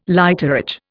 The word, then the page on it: lighterage: Indian